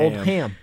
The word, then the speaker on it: Oldham: No, it's not Oldham. It's Oldham. – Gross ham. – Oldham. Oldham. – Yeah, it's two hard syllables.